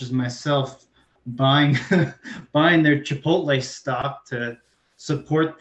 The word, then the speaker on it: Chipotle: That's how you pronounce it. Chipotle.